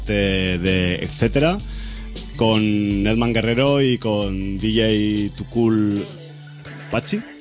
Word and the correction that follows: Paxil: Paxil. – ¿Lo digo bien? – Paxil. Paxil.